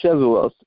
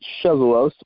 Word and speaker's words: Shavuos: He writes, the name should be pronounced Shavuos, vowelized with the Shva under the shin meaning oats, rather than Shavuos with a kamas on the shin meaning weeks.